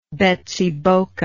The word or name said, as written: Betsiboka